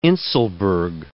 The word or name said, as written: inselberg